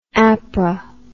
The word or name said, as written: apra